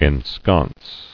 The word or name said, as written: Ensconcing